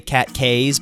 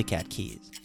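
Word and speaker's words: Cat Cays: But it's spelled CATCAYS. So a non-local would say Cat Keys, but it's actually Cat Keys.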